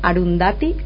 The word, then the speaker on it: Arundhati: Mi recomendación es El dios de las pequeñas cosas, de la escritora Arundhati Roy.